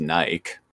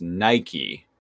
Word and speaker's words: Nike: I mean, that word, looking at it, yeah, would probably be pronounced Nike. Like, you know, Mike, bike, etc. – Like, yeah, yeah, exactly. – Right. But it is a name of a brand, and that brand name is pronounced Nike.